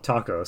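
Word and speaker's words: THAC0: so I pronounce it taco and then I correct myself and say thaco.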